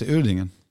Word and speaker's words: Uerdingen: Och sen så, nu får du hjälpa mig med uttalen här, så gick du till KFC Uerdingen. Ja, exakt.